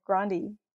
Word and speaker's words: Grande: That apparently that's how you pronounce her name. That's how you properly pronounce her last name, Grande.